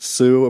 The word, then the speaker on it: Suh: Which I know it's pronounced Sue, apparently. I've been told that, yeah. But, like, I prefer Suh, because it's like Suh.